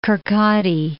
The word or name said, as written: Kirkcaldy